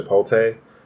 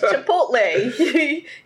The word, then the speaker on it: Chipotle: Like, when you go to Chipotle, like... – Why do you say it like that? – Well, it's a different cheese. I'm sorry. – I know. It's Chipotle. You added an extra T in there.